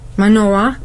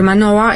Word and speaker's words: Manoah: So I think God find this faithful people who was Manoah, is the right pronunciation? – Manoah. – Yes, sorry, Manoah, and his wife.